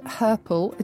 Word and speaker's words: herple: It's all about words and language, and it's called Something Rhymes with Purple, because something does rhyme with purple, doesn't it, Susie? – Yes, that is the old dialect word herple, admittedly not on the tongue every day, but to herple, spelt with an I, is to limp, to walk with a limp.